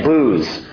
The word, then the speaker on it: Buz: Verse 23, by the way, you think Uz and Buz is bad? That's actually not the correct pronunciation. You ready for this? It's really Uz and Booz.